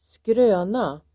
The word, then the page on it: skröna: [²skr'ö:na]